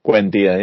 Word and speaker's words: Buendia: Buendia, yeah.